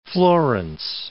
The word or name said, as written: Florence